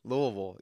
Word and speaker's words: Louisville: Say it correctly now, Louisville.